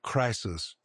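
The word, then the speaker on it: crisis (κρίσις): You pronounce it crisis. It's where we get the word crisis.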